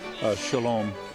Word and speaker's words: Cholame: It looks like Sholami, but it isn't. It's pronounced Sholam. It's spelled C-H-O-L-A-M-E.